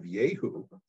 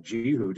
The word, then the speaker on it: Jehu: But Jeroboam um, ben Joash, son of Joash, was the fourth member of the house of Yehu. Um, you might pronounce that Jehu, depending on how you read the English uh, translations.